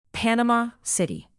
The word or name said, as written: panama-city